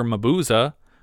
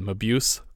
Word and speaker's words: Mabuse: Cinematographer Charlie Steinberger, only other title I recognized was The Death Ray of Dr. Mabuza. Thanks to Stephen for the correct pronunciation of Dr. Mabuza, which I think I previously called Mabuse.